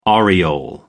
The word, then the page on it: aureole: American English